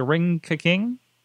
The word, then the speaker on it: Ring Ka King: Now, we know the, there was a partnership several years ago where it was a Ring Ka King, I believe. Is that is that the right pronunciation there? – Yeah, yes.